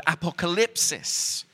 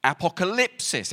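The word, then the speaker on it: apokalypsis (ἀποκάλυψις): And it's the Greek word apokalypsis. Apocalypsis. Everyone say apocalypsis. Okay, the emphasis is on the lip.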